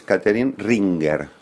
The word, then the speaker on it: Catherine Ringer: Aquí le dicen Catherine Ringer, pero para que se entienda bien es Catherine Ringer.